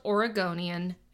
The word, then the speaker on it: Oregonian: So Bill Ward was a 49-year-old Oregonian, and yes, that is how it's pronounced,